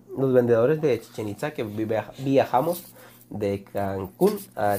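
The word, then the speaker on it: Chichén Itzá: De los sabios o brujos del, Del agua que se, y se pronuncia Chichen Itza.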